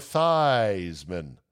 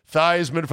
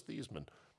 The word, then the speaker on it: Theismann: Joe Theismann used to be the quarterback at Notre Dame, and then in a failed bid to win the Heisman, said, no, you pronounce it Joe Theismann. Theismann for the Heisman. I thought it was Theismann. Not no more, it ain't.